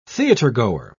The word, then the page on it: theatergoer: British English